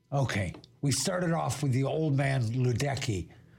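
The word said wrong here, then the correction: Luedecke: Ludica. Old man Ludica. I've been saying it incorrectly for years, and I was corrected in Mariposa a couple of years ago.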